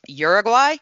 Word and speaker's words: Uruguay: And that is the country of Uruguay, also pronounced Uruguay, but I pronounce it by the name they prefer.